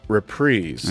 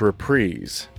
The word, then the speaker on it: reprise: So Loki Cat reprise, or sorry, I realize I've been saying this wrong. Loki Cat reprise. – I know, that always throws. – If we're me. – Talking about music, it's reprise.